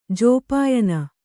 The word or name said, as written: ಜೋಪಾಯನ